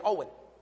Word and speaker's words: Jowan: You spell this guy's name, J-O-W-A-N. He says it so fast it sounds like he's saying Owen. J-O-W-A-N. His last name is spelled O-S-T-E-R-L-U-N-D.